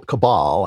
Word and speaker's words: cabal: Now, there's a once-in-a-lifetime opportunity to take this global cable of Satan-worshipping pedophiles out. And I think we have the president to do it. And so I'm very excited about that. – A global cabal, I think it's pronounced, of Satan-worshipping pedophiles.